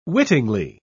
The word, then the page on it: wittingly: British English